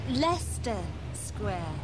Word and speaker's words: Leicester Square: How do you pronounce it? – Leicester Square. Yes, this is Leicester Square.